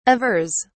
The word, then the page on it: avers: British English